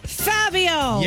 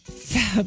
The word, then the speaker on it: Fabio: Kind of rhymes with radio, sort of, but not really. – Fabio! – Yes. – Fabio.